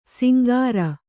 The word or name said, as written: ಶಾಂಕರ